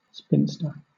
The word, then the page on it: spinster: British English